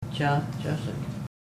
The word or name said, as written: chahchesek